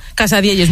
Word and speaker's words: Casadielles: Y nos trajo una cosa que se llama Casadielles, ¿no? ¿Lo digo bien? – Casadielles, muy bien dicho.